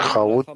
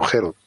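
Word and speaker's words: Herut (חרות): Harut, tallado en las piedras, no lo pronuncian Haut, sino Herut.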